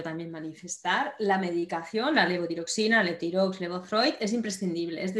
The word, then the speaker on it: Levotiroxina: Hemos hablado de la levotirotoxina, ¿lo he dicho bien? – Levotiroxina. – Levotiroxina, casi.